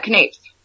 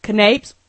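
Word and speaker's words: canapés: Oh my gosh, with a salmon, how you pronounce it, canapers? – Canapes. – Canapes, and you had roasted rosemary lamb over roasted vegetables.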